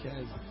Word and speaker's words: chasm: It is like a canyon, but it's a chasm. Am I pronouncing that wrong or what? Chasm. Amen. Thank you.